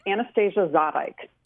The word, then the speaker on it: Anastasia Zadeik: Yes, it's Anastasia Zadeik.